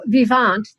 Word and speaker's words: Vivant: So Vivant, that's the way one would pronounce it because it's a French word. Means alive or living in French.